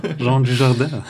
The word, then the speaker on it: Jean Dujardin: Ähm, oh, wir haben, einen, wir haben einen Namen Oscar richtig ausgesprochen. Ja, Jean Dujardin.